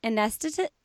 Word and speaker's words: anesthetist: You're a nurse anesthetist. Anesthetist is that is that how you say it? You said it perfectly.